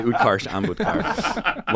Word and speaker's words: Utkarsh Ambudkar: And it's played by Utkarsh Ambudkar.